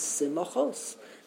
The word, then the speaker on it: simachos: the correct way to pronounce it is simachos.